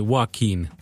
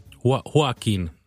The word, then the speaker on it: Joaquin: Úgy kell kiejteni, hogy nagyon érdekes, úgy kell kérteni, Joaquin. Joaquin. Joaquin.